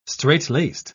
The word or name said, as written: straitlaced